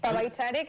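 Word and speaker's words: Pa' bailar: Hemos comenzado esta entrevista con Rabudiciu, una de esas canciones que está en el álbum Paiva Chari, ¿no? Si no lo he dicho malamente. – Pabaichare, que quiere decir para bailar.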